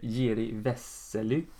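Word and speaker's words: Jiří Veselý: Eh, Delbonis förlorade sedan i finalen mot eh, Jiri Vesely.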